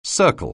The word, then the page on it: circle: anglais